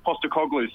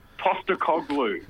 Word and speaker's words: Postecoglou: Yeah, that's right, Ange Postecoglou. So, Astapoglou, is that right? – Postecoglou. – Postecoglou, all right.